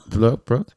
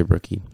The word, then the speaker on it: Brooke: How do I say her last name, bro? Brooke? Yeah. I was going to say Brookie.